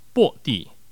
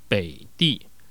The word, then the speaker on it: 北地: 这个地方的第一句的北地，如果要把这个北呢读成入声的话呢，它呢应该会读成薄地。但我为了避免大家在听觉上面，呃，有所谓的不理解或是歧义的发生，因此我还是读成现代的读音北地。